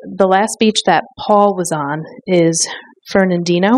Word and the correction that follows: Fernandina: Am I pronouncing that right? Fernandina Beach in Florida.